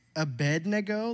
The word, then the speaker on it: Abednego: So starting in verse 1, we're going to talk about Shadrach, Meshach, and Abednego. And can we, I'm sorry, that's a Wednesday situation. It's Abednego, like Wednesday.